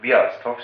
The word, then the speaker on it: Björstorp: Björstorp. – Björstorp ska det vara. – Det är där bor norra Björstorp och södra Björstorp.